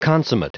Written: consummate

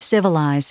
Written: civilize

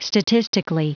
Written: statistically

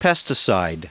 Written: pesticide

